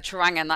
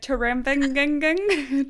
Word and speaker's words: Trawangan: Even the word Trawangan, that took me months to be able to pronounce that. – I'm always like Trawangan.